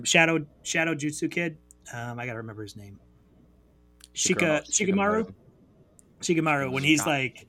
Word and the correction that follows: Shikamaru: Shikamaru. Shikamaru. – Oh, sorry, I was putting the accent on the wrong letter.